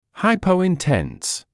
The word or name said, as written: hypointense